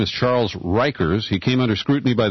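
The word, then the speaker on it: Riechers: And his name is pronounced Rikers.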